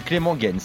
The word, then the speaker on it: Clément Gens: Alors d'ailleurs, je ne sais pas comment on prononce Clément Gens. Je sais pas comment on dit à la, belge. On dit Clément Gens, exactement comme tu l'as dit.